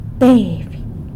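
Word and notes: teiby: San Lucas Quiaviní Zapotec

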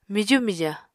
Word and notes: miju mija: Apatani

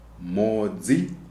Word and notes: Mhodzi: Shona

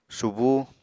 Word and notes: subú: Apatani

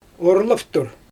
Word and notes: orlaftur: Xyzyl

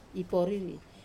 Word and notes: poriri: wamesa

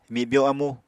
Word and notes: mibyo amu: Apatani

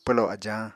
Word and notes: pwlo ajañ: Apatani

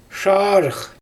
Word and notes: ʃaarɯx: Xyzyl

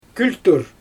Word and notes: kültur: Xyzyl

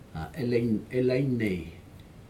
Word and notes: elainai: Aneityum

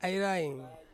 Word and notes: airaing: Ansus

